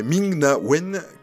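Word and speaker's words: Ming-Na Wen: Et encore un nom qui va me faire peur à prononcer, qui est Ming Na Wen, qui est dans la catégorie. Très bien prononcé.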